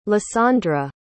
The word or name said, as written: lysandra